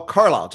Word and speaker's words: Carlisle: How do you say that? Calisthenics? – Oh, Carlisle, just Carlisle.